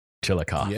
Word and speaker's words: Chillicothe: So eventually he gets moved to another like maximum security place called Chillicothe in Chillicothe, Ohio. Chillicothe, Ohio. Chillicothe.